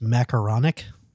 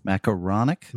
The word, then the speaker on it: macaronic: I believe it's pronounced macaronic.